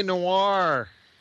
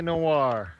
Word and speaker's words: Noir: It's L.A. Noir. L.A. Noir. – Noir, sorry. – And Noir means black in French.